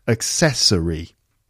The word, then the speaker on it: accessory: Accessory. Four syllables, and it's the second syllable again. Accessory.